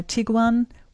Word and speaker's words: Tiguan: Afternoon, I'd like to replace my Tiguan with a, is that how you say it?